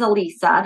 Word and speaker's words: Elisa: But growing up, my name was Elisa. That's my name, E-L-I-S-A. And a lot of people would pronounce it wrong. Alyssa, Elise, whatever sure.